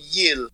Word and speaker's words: Gilles: And I only know one guy named Gil, and he's French, and you call him Yil. His name is Gilles.